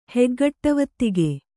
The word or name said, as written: ಹೆಗ್ಗಟ್ಟವತ್ತಿಗೆ